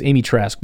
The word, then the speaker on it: Amy Trask: Again, you can take, is there Amy Trask? Is that her name? I don't want to say her name wrong and then just keep saying it wrong. Yes, Amy Trask.